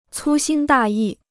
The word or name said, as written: 粗心大意